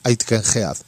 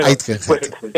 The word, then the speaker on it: Aitkenhead: Creo que se pronuncia Aitken Heath.